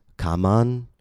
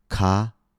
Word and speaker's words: common: The word is not pronounced kaman or kamon, but common. Ka-mun, common.